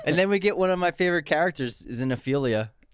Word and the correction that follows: Xenophilius: Is it Xenophilius or Xenophilius? No, it's Xenophilius. – Oh, yeah, you're right.